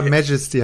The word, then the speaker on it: Majesty: ganz andere Betonung. Ja, Majesty heißt das Ding.